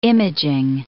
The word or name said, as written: imaging